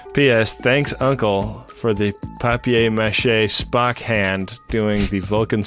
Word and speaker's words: papier-mâché: It's papier-mâché. – Papier-mâché. – This is a P-A-P-I-E-R, which is pronounced papier.